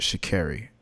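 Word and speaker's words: Sha'Carri: "My family has kept me grounded," Richardson, whose first name is pronounced Shikari. Oh, so I even pronounced it wrong.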